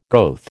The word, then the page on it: goeth: American English